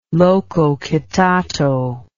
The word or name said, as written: lococitato